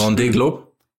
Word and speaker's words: Vendée: Und Vendée Globe? – Der Globe, wie der Globe.